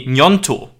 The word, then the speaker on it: Gnonto: Vielleicht noch ganz kurz zu Wilfried Gnotto. Gnonto. Ich behaupte jetzt einfach mal, dass man ihn so ausspricht. Das wäre die italienische Aussprache, Gnonto wahrscheinlich. Mhm.